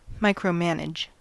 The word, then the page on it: micromanage: American English